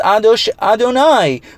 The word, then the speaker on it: Adonai: It's spelled Yud Hei Vav Hei and pronounced Adonai.